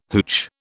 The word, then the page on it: hootch: British English